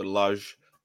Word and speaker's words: Lage: In Portugal, it would be pronounced Brunelage, so Lage. But in England, it's Bruno Lage.